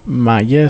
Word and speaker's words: Maja: É um nome estranho, difícil de dizer, nem sequer se estarei a dizer corretamente ou não, provavelmente que não, mas Maya, seguro que sim, Maja, é como se escreve, Maya Ratakj. Exatamente, assim estará mais correto.